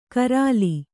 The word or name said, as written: ಗರಲ